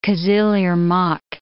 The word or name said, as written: Kizil Irmak